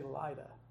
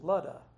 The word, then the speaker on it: Lydda: And now we look at this and we say, we want to say Lydda or Lydda, but it's Ludda. The Y is, in this particular case, is pronounced as a U.